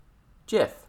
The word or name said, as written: jif